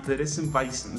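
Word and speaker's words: Theresienwiese: Se nos complica a los mexicanos, pero finalmente se llama Theresienwiesen.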